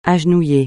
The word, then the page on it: agenouiller: French